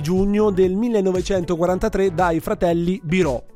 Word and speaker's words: Biro: in realtà Biro, scusa, non okay, Biro. – Perché l'accento è chiuso.